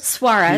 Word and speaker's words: Soares: Well, then I want to jump to Ulysses Soares. I know I said that wrong. – Suarez.